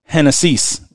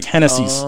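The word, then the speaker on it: Génesis: So in Spanish, Genesis is pronounced Hennessy's. Like Hennessy's.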